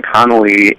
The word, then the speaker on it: Connolly: And my last name, Connolly, is spelled C-O-N-N-O-L-L-Y. So it's two N's, two O's, two L's.